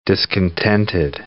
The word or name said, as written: discontented